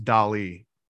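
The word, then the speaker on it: DALL-E: It's pronounced Dali.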